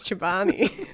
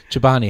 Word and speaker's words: Chobani: You should have gone first so I could have asked how to pronounce Chobani. – Chobani, how do you pronounce Chobani? – Oh, I don't know.